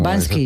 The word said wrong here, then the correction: Banksy: Es Banksy.